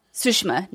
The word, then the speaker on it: Tsushima: Ghost of Tsushima.